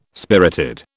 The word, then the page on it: spirited: British English